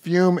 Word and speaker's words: Fum: Fume is spelled F-U-M, pronounced Fume.